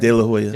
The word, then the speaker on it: De La Hoya: I watched a little clip of De La, how do you say his name? – De La Hoya. – De La Hoya.